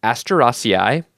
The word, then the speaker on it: Asteraceae: Oh, I nailed the pronunciation that time. So, anyway, that's Asteraceae. And now it's time to hear from our guest.